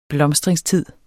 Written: blomstringstid

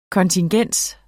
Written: kontingens

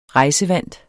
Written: rejsevant

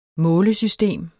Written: målesystem

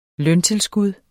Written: løntilskud

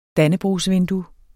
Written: DANNEBROGSVINDUE

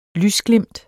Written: lysglimt

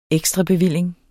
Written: ekstrabevilling

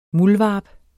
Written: muldvarp

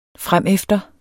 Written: fremefter

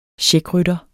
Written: CHECKRYTTERNE

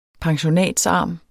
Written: pensionatsarm

